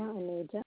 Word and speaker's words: Aneja: Lena Aneja.